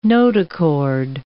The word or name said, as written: notochord